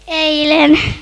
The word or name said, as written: eilen